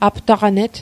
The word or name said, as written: aptaqanet